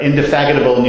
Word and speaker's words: indefatigable: Yeah, Frank our indefatigable news editor. I actually said that right.